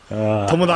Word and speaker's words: Tomodachi: Tomo. Tomo. There's no A in there. Tomo. Tomodachi. – I'm just messing with you. – Well, it's funny. Even some of the Nintendo tweets. People mispronounced it. It's like, you work for Nintendo. You are a translator for Nintendo in the treehouse. Don't mispronounce Tomodachi Life. It's Tomo. Not Tamagotchi.